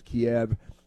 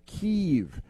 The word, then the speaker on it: Kyiv: And they say, oh, well, no, we didn't change the pronunciation. We've just had it wrong for centuries, and now we're going to start pronouncing it correctly. So we're going to call it Kiev, Kiev, like K-E-E-V-E or something. And I always refuse to call it Kiev because that's ridiculous. It's called Kiev. Just look at the spelling and forget about it.